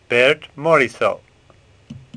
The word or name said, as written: Morisot